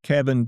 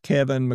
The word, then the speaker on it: Keven: There's just Kevin D. McQueen, which is what I call my comedy page, so-called. And there's Kevin McQueen, the author page. I should note my first name is spelled K-E-V-E-N, which might help you find me.